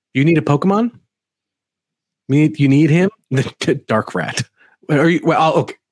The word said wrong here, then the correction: Darkrai: I can't tell if Calder in the chat is making fun of my pronunciation of Darkrai. Yeah, okay. No, he was. Thank you, Calder. Darkrai is another great one.